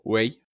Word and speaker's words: 喂: 喂 Hey.Al contestar por teléfono, se pronuncia como wei.